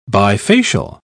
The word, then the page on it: bifacial: British English